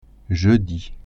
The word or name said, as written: jeudi